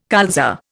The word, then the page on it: colza: Indian